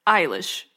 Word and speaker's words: Eilish: According to this, it's Eilish. – Yeah. – Is the correct pronunciation.